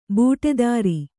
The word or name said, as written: ಬುಟೇದಾರಿ